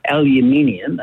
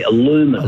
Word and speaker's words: aluminium: One is we say aluminium, Americans say aluminum.